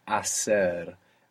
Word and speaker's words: hacer: To do or to make in Spanish is hacer, and it has a silent H. It's HACER. So in most of Spain, actually, that would sound hacer, but hacer in most of the Spanish-speaking world.